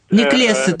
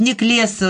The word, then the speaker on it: Неклеса: И Александр Неклеса приводит... – Неклеса только его фамилия. Александр Иванович Неклеса, у него другое ударение.